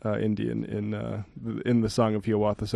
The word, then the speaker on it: Hiawatha: Speaking of bold, I refrained from asking last week, but is it really Hiawatha as opposed to Hiawatha? – That Longfellow himself pronounced it Hiawatha.